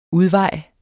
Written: udvej

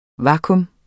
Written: vakuum

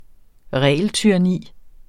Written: regeltyranni

